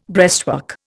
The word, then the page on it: breastwork: Indian